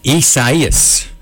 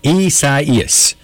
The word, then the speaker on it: Isaias: Let's see, I wrote it down here on how to pronounce this, so just bear with me. Isaias. Yeah, that's how it goes. Isaias is the hurricane.